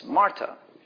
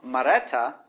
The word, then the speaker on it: Marta: And in Marta, Marta is actually the feminine version of Mar, and comes over into Aramaic as Marta. So what we have there, interestingly, with Marta, or another way to pronounce it, depending on where you put the vowels and such, or is Mareta, is mistress.